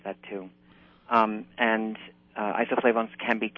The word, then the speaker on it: isoflavones: Isoflavones, which come from legumes, and you mentioned soybeans. Tell us about and it's I S O F L A V O N E S, pronounced isoflavones. Talk to us about those.